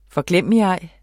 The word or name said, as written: forglemmigej